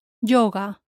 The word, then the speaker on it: yoga: Notice how the Y-O is yo. Yoga.